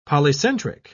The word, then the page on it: polycentric: British English